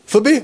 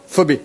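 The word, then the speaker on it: Phœbé: Donc numéro 1, on va regarder, alors je ne sais même pas comment on prononce en français, Phébé, Phoébé. Comment on dit en français? Phoebé. Phoebé, on est tous d'accord? Phoebé, d'accord. Regardez les versets 1 à 2. Paul écrit, « Je vous recommande Phoebé,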